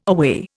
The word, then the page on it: aweigh: Indian